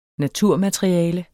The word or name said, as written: naturmateriale